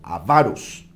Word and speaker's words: avaros: Eh, no, la tilde no está en la A, la primera A, el acento está en la segunda sílaba, avaros.